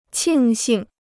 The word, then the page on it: 庆幸: qìng xìng